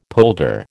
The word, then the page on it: polder: American English